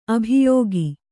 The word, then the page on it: ಅಭಿಯೋಗ: abhiyōga